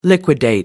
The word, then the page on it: liquidate: American English